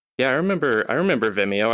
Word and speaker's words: Vimeo: Is there this Vimeo... How do you say it in English? Vimeo? Vimeo? – Oh, Vimeo. – Vimeo, yeah.